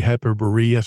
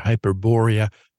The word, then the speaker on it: Hyperborea: You say Hyperborea. Some people call it Hyperborea. What is the correct pronunciation of the term? – Well, I think you can say both, actually.